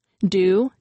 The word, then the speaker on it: due: Do, D-U-E, is pronounced just like Jew, J-E-W.